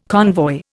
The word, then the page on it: convoy: Indian